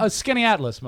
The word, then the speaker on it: Skaneateles: Skaneateles.